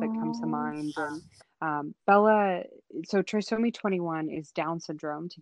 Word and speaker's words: trisomy: Trisomy? Do I say it right? Trisomy? – Yeah, so trisomy 18.